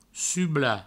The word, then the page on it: sublar: provençal; subla